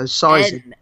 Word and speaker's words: Sysun: His name is spelt S-Y-S-U-N, so Sizen.